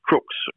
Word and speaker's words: Crux: Its correct name is Crooks, or you could pronounce it Crux, so like a crucifix.